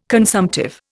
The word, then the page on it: consumptive: Indian